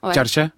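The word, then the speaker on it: Çarşı: Pour le fameux groupe en particulier, alors moi je le prononce très mal, Charche. – Charche, ouais.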